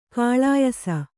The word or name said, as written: ಖುಲಾಯಿಸು